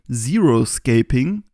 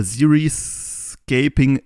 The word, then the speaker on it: Xeriscaping: Genauer haben wir uns da was Spezielles rausgesucht, nämlich das sogenannte Zeroscaping, spricht man es im Englischen aus, oder Xeriscaping, es gibt da noch nicht so die genaue Aussprache, geschrieben wird Xeriscaping.